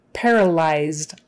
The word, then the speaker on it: paralyzed: Paralyzed, eyes, eyes, that YZ is like eyes.